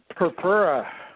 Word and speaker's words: Purpura: Purpura.